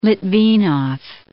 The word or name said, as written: Litvinov